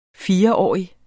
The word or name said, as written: fireårig